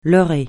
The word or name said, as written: leurrer